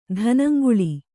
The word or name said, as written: ಧನಂಗುಳಿ